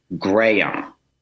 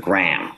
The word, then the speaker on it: Graham: And I was mortified he explained that it was Graham, which is sort of three syllables instead of Graham, which is one, the way I was saying my upstate New York twang.